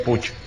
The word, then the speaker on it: Puig: Simplemente una curiosidad, sí, en catalán se escribe Puig, pero se pronuncia como lo escribes tú, que es Puch.